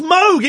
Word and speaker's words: Moog: There's a synthesizer called the Moog. – Familiar. – Yeah, it's not. It's called the Moog. – What? – Yeah, I know! It's pronounced Moog. It was Robert Moog. And it's spelled M-O-O-G. Oh, and everyone calls it a Moog. It's not a Moog. It's a Moog.